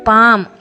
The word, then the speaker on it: palm: GUARD ಗಾರ್ಡ್, BALM ಬಾಮ್, PALM ಪಾಮ್, ಈ ಥರದ ಒಂದಷ್ಟು ಪದ ಹೇಳಿದೆ.